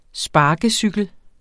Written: sparkecykel

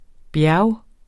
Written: bjerge

